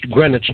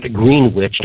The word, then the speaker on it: Greenwich: Uh, that's uh, pronounced Greenwich, not Greenwich.